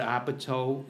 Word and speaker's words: Apatow: Judd Apatow, am I pronouncing it correctly? – Yeah.